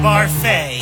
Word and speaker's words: Barfy: It's pronounced Barfay. There's an accent, a do.